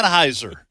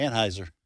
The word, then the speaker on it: Anheuser: Anheuser. Yeah. I've been mispronouncing it all this time. So my apologies to you people who are listening to this program and screaming at the radio because I mispronounced.